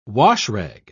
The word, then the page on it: washrag: British English